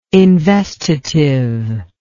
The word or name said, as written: investitive